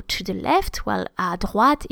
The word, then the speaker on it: droite: And droite is spelled D-R-O-I-T-E. Yes, because I sound the T. You hear when I say droite or à droite, I sound the T. Therefore, it's not final. It is followed by an E, which is not pronounced.